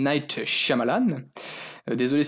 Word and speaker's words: Shyamalan: Le, Y est muet, M Malan.